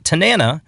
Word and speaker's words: Tanana: That one's called Tanana. That rhymes with banana.